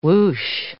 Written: whoosh

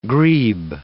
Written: grebe

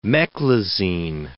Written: meclizine

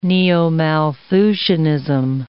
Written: neo-Malthusianism

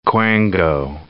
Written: quango

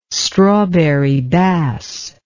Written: strawberry bass

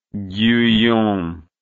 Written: guyon

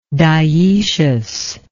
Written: diecious

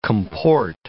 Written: comport

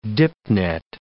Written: dipnet

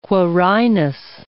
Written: Quirinus

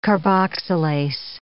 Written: carboxylase

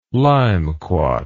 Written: limequat

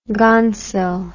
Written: gonsil

